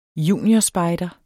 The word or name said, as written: juniorspejder